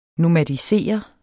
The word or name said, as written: nomadisere